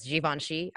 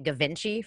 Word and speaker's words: Givenchy: you know how many years it took me to figure out that Givenchy was Givenchy? I was calling it Gavinci for like half my life.